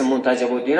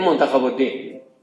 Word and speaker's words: منتجب الدین: خدا رحمت کنه آقای دوانی رو و اون اوائل که ما در قم مشغول همین کارهای تراجمی و رجالی و اینا شدیم، اصلا فهرست منتجب الدین هم درست تلفظ نمی کرد، نمیدونستیم، میگفتیم منتخب الدین. خودشون میگفت که تا مدت ها ما میگفتیم فهرست منتخب الدین.